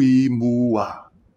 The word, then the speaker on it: imua: Holo holo vaka, awe awe, nuku imua. When we see an I, as we do here, remember it is pronounced E, like in the word easy.